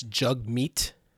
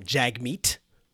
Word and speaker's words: Jagmeet: That's Jugmeet, not Jagmeet. Get his name right. Put some respect on his name. So it's Jugmeet. So he's a Canadian lawyer and politician, serving as the leader of the New Democratic Party since 2017. And and as his Twitter profile says, it's pronounced jug meat like hug.